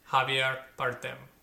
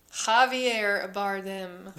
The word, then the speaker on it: Javier Bardem: Javier. You're saying it like a weird... – I don't know. Just weird. I'm going to say it like an American, badly. – Javier Bardem. You got it.